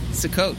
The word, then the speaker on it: Sukkot: Sukkot. – Happy Sukkot. Well, just tell me about your rig. – Well, usually people have their little booth that they make for the holiday of Sukkot.